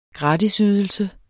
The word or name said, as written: gratisydelse